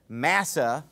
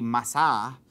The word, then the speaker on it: Massah: No, but anyway, you know, the Hebrew way to pronounce it would be Massah.